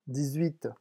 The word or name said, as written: dix huit